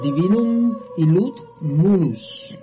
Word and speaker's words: Divinum Illud Munus: Divinum Illud Minum. ¿Cómo se pronuncia, padre? – A ver, es que no, ¿dónde la tenés? – Aquí la tengo. – A ver, dice Divinum Illud Munus.